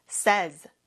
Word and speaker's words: says: 그럼 발음이 says로 바뀝니다. 같이 해볼까요? says, says. Y 발음이 조금 약해졌죠?